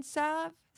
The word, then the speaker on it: salve: The only thing I'd have to say is that I usually don't pronounce the L... In salve? So I say salve? – More salve, not salve.